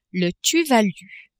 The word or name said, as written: Tuvalu